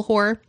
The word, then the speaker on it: Hoare: H-O-A-R, like Boar, with an H. – Okay, Hoare? – Got it.